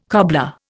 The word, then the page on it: cobbler: Indian